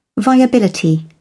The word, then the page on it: viability: British English